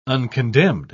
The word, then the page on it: uncondemned: British English